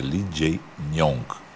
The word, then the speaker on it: Lee Jae-myung: E a gente aprendeu, eu pronunciei errado, é Lee Jae-myung.